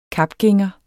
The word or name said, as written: kapgænger